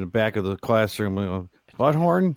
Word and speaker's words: Buthorn: His victims were Jeanette Gennett, age 58, her daughter, age 30, Candace Coons, and Coons' boyfriend, age 36, Andrew Butthorn. It might be pronounced. It might be pronounced. It might be pronounced boothorn, but it's butthorn. B-U-T-H-O-R-N.